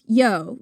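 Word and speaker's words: Io: That's spelled I-O, Saturnalia. And it's pronounced yo, right? – Yep, it's pronounced yo.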